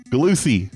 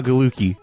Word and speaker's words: Gallucci: So what I'm getting at is that 3 days ago, some guy named Gus Galusi, wait, did I say that completely wrong? Galusi, not Galuki.